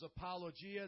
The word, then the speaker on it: apologia: This is apologia. That's exactly how you pronounce it.